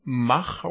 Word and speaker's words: Mach: Äh, dann der, der Mach Rider. Wie spricht man Mach auf Englisch aus? Mac. Mac Rider aus Mac Rider.